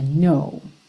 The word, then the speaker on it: know: Então, embora ele tenha esse K no começo, N-O-W, o K não é pronunciado e ele não vai ter a pronúncia do agora, now, aberto, uma pronúncia fechadinha, know, ok?